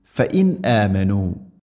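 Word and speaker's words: ഫഇൻ ആമനു (فَإِنْ آمَنُوا): ഫഇൻ ആമനു, ഇവിടെ ഓതുന്ന സമയത്ത് ഫഇന്നാമനു എന്നല്ല പറയുക, ഫഇൻ അ. സാക്കിന നൂന് ശേഷം ഹംസ വന്നാൽ ഇർഹാർ, വെളിവാക്കി, അഥവാ ഹംസയെല്ലാം കൃത്യമായിട്ട് ഉച്ചരിക്കണം.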